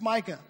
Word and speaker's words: Micah: I'm like, it's Micah. Oh, Micah. There's no R.